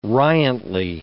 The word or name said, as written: riantly